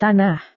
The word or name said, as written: tanah